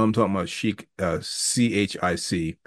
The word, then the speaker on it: Chic: It was spelled chick, which confused the heck out of me. Once again, as a 9-year-old, it's like, Mom, that says chick. It's pronounced chic, baby. But it says chick.